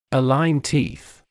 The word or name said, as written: align teeth